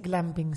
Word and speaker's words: glamping: Acá me corrigen que es glamping. Si yo lo dije bien, pero Angie lo dijo mal. Es glamping. – Glamping. – De glamour.